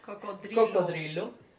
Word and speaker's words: cocodrilo: How do you say it in Spanish? – Cocodrilo.